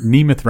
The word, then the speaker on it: Nemeth: I mean, Nemeth rhymes with behemoth.